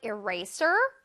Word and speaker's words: eraser: 네, 이레이저라고 우리 친구들 많이 발음하더라고요. 이레이저, 이레이저 절대 아닙니다. 이레이저 선생님 별로 좋아하지 않아요. 자, one more time. Eraser 라고 발음해 볼게요. Eraser. 잘했어요. One more time. Eraser. 자, 쓰쓰쓰 소리를 강렬하게.